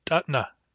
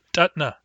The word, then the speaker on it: tatna: The singular form of child is tatna. Tatna.